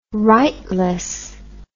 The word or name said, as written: rightless